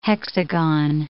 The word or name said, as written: hexagon